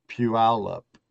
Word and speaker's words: Puyallup: Puyallup.